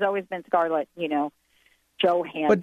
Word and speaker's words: Johansson: Another Scarlett Johansson movie. And by the way, my Scandinavian friend said, do not pronounce her name like that, please. It's Scarlett Johansson.